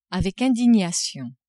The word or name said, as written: avec indignation